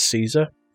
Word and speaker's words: Caesar: Now you're going to have to tell me how to say this properly, but I've always said it's Caesar. – Caesar's correct, yeah. Like the Emperor, or the Palace, or the Salad.